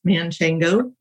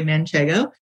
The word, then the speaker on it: Manchego: We get a lot of people who come in and look for smoked Gouda, or the inappropriately pronounced Manchego, which is really Manchego.